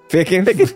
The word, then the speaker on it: Ficken: Okay. Fizzin? – Ficken. Ficken, like, hey, Marjo Ficken.